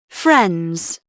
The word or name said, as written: friends